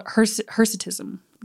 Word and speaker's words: hirsutism: Excess hair growth is called hirsutism. That's how you say it.